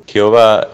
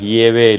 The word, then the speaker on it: Yahvé: Jehová está mal traducido, en realidad es Yahvé la verdadera traducción, mejor dicho, la verdadera pronunciación.